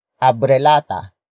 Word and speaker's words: abrelata: It is the focus marker that introduces the main topic of this sentence, and that is abrelata. Abrelata. Abrelata.